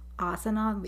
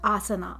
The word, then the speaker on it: asana: the breathing in and breathing out as I as I did my yoga, like each asana, each posture. – Wait, how do you say that word? – Asana. – Asana. – Yoga asana, yoga asanas. – Okay.